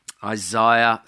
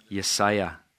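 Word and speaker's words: Jesaja: Isaiah 60. Yesaya. Is that how you say it, Morten? Yesaya? Yesaya. Yes. Very good. Not Isaiah. Isaiah.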